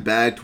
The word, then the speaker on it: Bakkt: For a long time, BACT, which is backed by the Intercontinental Exchange, ICE, and it's spelled B-A-K-K-T,